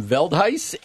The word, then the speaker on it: Veldhuis: Specifically, we are being joined by Jim Veldhuis and Tom Lewis.